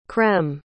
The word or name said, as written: crème